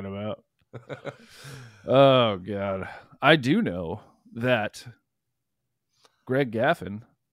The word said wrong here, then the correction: Graffin: Graffin. – Graffin? Did I say that wrong? It's not Gaffin? – It's not. – Greg Graff. Oh, that's. – Wait, am I saying it wrong? – What's your name, Greg? I know you're listening. – No, I'm right. Okay, Graffin. – Okay. – I'm right. Greg Graffin.